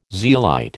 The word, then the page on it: zeolite: American English